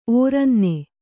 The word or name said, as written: ಓರನ್ನ